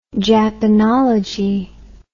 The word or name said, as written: japanology